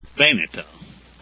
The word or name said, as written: veneto